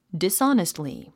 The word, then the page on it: dishonestly: disάnistli; ディスオニストリィ